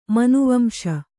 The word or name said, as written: ಮನುವಂಶ